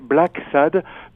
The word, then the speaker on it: Blacksad: Alors, c'est comme l'anglais, Black Sad, donc triste noir. – D'accord. – B-L-A-C-K-S-A-D.